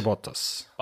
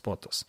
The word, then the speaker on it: Bottas: Last year, the biggest problem was that, so Hamilton qualified second, but Bottas, I'm going to pronounce his name properly this year, right? Bottas? Valtteri Bottas. – Bottas? – Bottas.